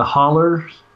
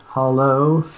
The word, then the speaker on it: holler: I grew up in the holler, in one particular holler. We pronounce it with an er sound on the end, not a hollow, as may seem proper.